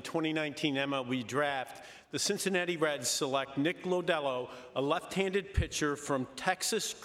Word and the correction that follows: Lodolo: Ja, nu tror jag kommissionären fick lite fel uttal där på Cincinnati Reds första val där. Nick Lodolo, nummer sju totalt i den här draften.